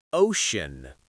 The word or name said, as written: ocean